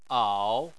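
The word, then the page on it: ao: tone 3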